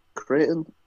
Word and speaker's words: Creighton: Creighton. Am I saying that right? Creighton Duke. – Creighton? Yeah, that sounds it.